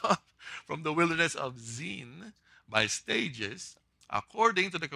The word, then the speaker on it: Zin: It's not pronounced sin. It should be seen. And it should be a Z instead of a S. That's the original. It's the wilderness of Zin.